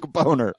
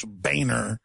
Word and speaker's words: Boehner: But he even had a campaign when he first ran for Congress saying it's Boehner. Not Boner.